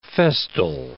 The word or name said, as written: festal